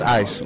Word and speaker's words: Ise: My name is Ice Life. – Spell it. – I-S-E is my first name. My last name, Life, L-Y-F-E.